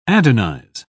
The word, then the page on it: adonize: British English